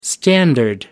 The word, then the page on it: standard: American English